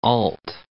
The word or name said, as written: Olt